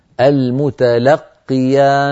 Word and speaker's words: المتلقي: إذ يتلقى المتلقي، المتلقي لقي قافان المتلقي، أما إذا قلنا إذ يتلقى المتلقيان يعني القاف الأولى الساكنة كأنها يعني ذهبت أو يعني صوتها صار ضعيفا،